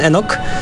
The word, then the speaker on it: Enoch: Naszym bohaterem jest Enoch, w dosyć nietypowy sposób pisany przez CH, ale wymawiany Enoch.